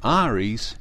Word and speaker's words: Ares: The name Ares is ancient Greek for Mars, which is a long-term aspirational goal here, and is pronounced the way you do when naming the star Antares, as opposed to naming the zodiac constellation Aries.